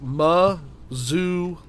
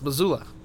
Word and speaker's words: Missoula: How do you say Missoula, Montana? It says Missoula. You're right. – All right. – It's Missoula. Wow.